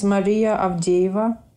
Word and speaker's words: Maria Avdeeva: Yes, my name is Maria Avdieva.